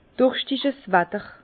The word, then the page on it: Durschtig's Watter: Bas-Rhin; Herrlisheim